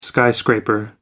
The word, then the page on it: skyscraper: American English